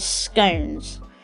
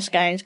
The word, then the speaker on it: scone: So, again, out of interest, where are you on the scones gone debate? How is it pronounced to you? How do you pronounce it? Do you pronounce it as scone, as in cone? Or scone, as in gone?